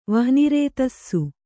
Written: ವಹ್ನಿರೇತಸ್ಸು

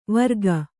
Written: ವಾರಕ

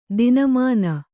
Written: ತನಮನಿ

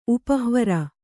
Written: ಉಪಹ್ವರ

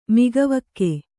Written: ಮೊಗವಿಕ್ಕು

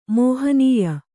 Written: ಮಹನಿೀಯ

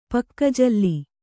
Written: ಪಕ್ಕಜಲ್ಲಿ

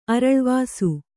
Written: ಅರಳ್ವಾಸು

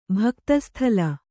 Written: ಭಕ್ತಸ್ಥಲ

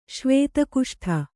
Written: ಶ್ವೇತಕುಷ್ಠ

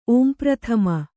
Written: ಓಂಪ್ರಥಮ

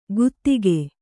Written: ಗುದಿಕೆ

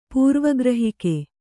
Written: ಪೂರ್ವಗ್ರಹಿಕೆ

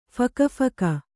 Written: ಫಕಫಕ

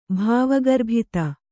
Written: ಭಾವಗರ್ಭಿತ

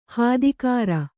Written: ಹದಗಾಱ

